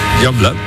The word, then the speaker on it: Youngblood: Pero luego, ¿cómo se pronuncia? – Youngblood.